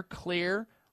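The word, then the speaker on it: Xlear: You spray in there, clear, rescue nasal spray. Clear is spelled with an X. X-L-E-A-R, rescue nasal spray.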